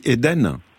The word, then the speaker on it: Hayden: Et vous avez donc créé les jardins, on dit Eden? – Ouais, les jardins d'Eden, c'est mon fils. – Mais alors c'est pas le paradis, hein, ça s'écrit H-A-Y-D-E-N, mais vous allez me dire c'est le paradis.